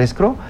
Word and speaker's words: escrow: La semana pasada traía unos clientes con una cosa que le llamaron escrow. Dices tú, ¿qué es eso? Ah, bueno, pues es una figura totalmente gringa. El escrow, para que no nos... se escribe E-S-C-R-O-W, escrow.